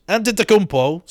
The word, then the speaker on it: Antetokounmpo: And I want to see the Greek Freak, Giannis Antetokounmpo.